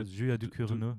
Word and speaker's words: Ducournau: Du, Julia, Julia Ducournau.